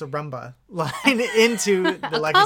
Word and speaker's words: Roomba: Sure, Roomba, okay.